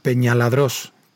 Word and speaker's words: Peñaladrós: Bueno, y nos queda la cascada de Peñadros, eh, perdón, Peñaladrós, que me he comido la sílaba.